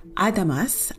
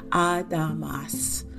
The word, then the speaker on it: Adamas: We first have Adamas, and I'm saying that by the kind of Korean pronunciation, how they're saying it in the drama, Adamas.